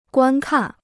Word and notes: 观看: guān kàn

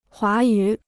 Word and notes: 华语: huá yǔ

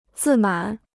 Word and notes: 自满: zì mǎn

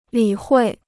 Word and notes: 理会: lǐ huì

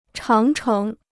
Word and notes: 长城: cháng chéng

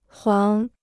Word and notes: 黄: huáng

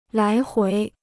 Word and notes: 来回: lái huí

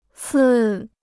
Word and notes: 次: cì

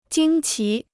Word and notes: 惊奇: jīng qí

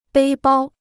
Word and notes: 背包: bēi bāo

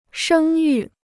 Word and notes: 声誉: shēng yù

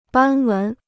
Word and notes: 斑纹: bān wén

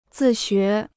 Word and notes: 自学: zì xué

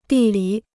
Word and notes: 地理: dì lǐ

